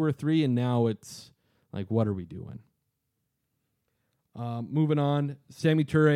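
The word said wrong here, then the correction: Samori: I didn't pronounce that correctly. – No, Samari. – Samari Ture. There we go.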